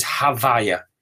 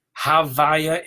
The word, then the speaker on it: Havaya: The word that's used is Havaya. Havaya is the way we mispronounce the ineffable tetragrammaton in the teachings of Kabbalah and Hasidus.